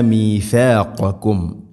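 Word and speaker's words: മീഥാഖകും (ميثاقكم): സൂറത്തുൽ ബഖറയിലെ 84-ാമത്തെ ആയത്ത്, വഈദ് അഖദ്ന മീഥാഖകും. ഇവിടെ ശ്രദ്ധിക്കേണ്ടത് വഈദ് അഖദ്ന, അഖദ്ന ദാലിൻ്റെ ഉച്ചാരണമൊക്കെ ശ്രദ്ധിക്കണം. മീഥാഖകും, മീഥാഖകും എന്നല്ല, മീഥാ മീ സീനാവരുത്. അതുപോലെ ഖാഫും കാഫും മഖ്റജ് അടുത്തുള്ള അക്ഷരമാണ്. മീഥാഖകും, ഖാഖും, മീഥാഖകും.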